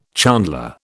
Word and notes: chandler: British English

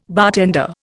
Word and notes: bartender: Indian

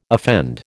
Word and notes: offend: American English

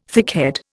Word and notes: thickhead: Indian

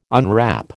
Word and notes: unwrap: American English

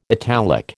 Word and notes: italic: American English

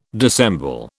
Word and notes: dissemble: British English

